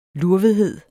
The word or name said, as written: lurvethed